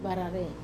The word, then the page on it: varare: Wamesa